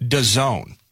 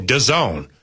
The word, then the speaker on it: DAZN: I guess, according to the press release, you pronounce it DAZN. – Oh, DAZN.